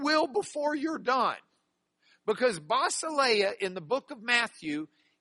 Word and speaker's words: Basileia: Basileia is the way we would pronounce this. Basileia. Say it with me. Basileia.